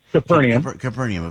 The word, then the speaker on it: Capernaum: Capernaum, of course.